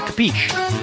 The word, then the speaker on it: capiche: Capiche? – Okay, great. – Say capiche. – Okay, thanks. – No, you're supposed to say capiche.